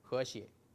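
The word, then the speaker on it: he xie (和谐): stands for censorship because river crab in Chinese has the same pronunciation with the state ideology harmony, he xie.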